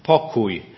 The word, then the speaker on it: pokój: In Polish, for those of us who are going to the international convention, you may want to practice this word, pokój, pokój. And if you see these three letters, K-O-J, or koj, as we might actually pronounce them in Polish, or kuj, because there is a little differentiation.